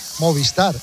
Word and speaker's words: Movistar: Hay mucha gente que pronuncia mal Movistar. Es Movistar. Y la gente dice Movistar.